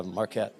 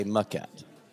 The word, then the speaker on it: Maquette: Yeah, Marquette. It's the most mispronounced thing. I would say that people say Marquette more than they say Maquette.